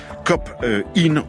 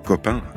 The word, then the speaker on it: COP IN: Elle, entre autres, parle de COP IN ou COP 1, comme on veut. Ça s'écrit COP plus loin, IN, I-N. Mais on aime bien dire COP 1 à Melun.